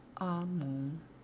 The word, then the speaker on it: Ambum: 他的名字是。 A M B U M，发音是阿蒙。